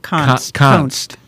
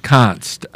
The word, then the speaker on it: Konst: And I forget how Nomiki Konst. – Konst, I think, is the way the young lady's name is pronounced.